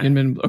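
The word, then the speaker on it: YInMn: Is it Yinmin Blue? – Yeah. – Yinmin, okay.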